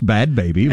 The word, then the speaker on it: Bhad Bhabie: I think it's pronounced Bad Baby, but it's spelled...